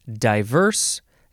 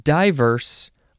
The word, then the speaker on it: diverse: You can emphasize either syllable, diverse, diverse, or diverse. No long I sound. D-I-V-E-R-S-E.